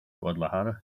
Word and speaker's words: Guadalajara: Right, moving on, I think we'll move to Guadalajara. – Guadalajara, yep. I think that's how you say it.